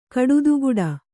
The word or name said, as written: ಕಡುದುಗುಡ